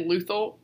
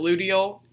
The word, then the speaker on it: luteal: I pronounced two words wrong I said luthal instead of luteal, and I think I said follicular, and it's follicular.